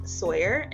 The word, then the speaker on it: Sawyerr: I am on Instagram and Twitter at Hann Sawyerr, and Sawyerr is spelled with two R's, not a typo. So HannSawyerr, and I'm also on TikTok. and that's just my name, Hannah Sawyerr, Sawyerr.